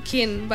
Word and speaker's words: Keane: No, és que s'escriu K-E-A-N-E. És que potser no ho pronuncio bé. No, és així. – Kin. – Bueno, doncs Kin. – Kin, els americans.